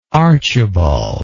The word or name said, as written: archibald